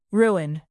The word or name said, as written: ruin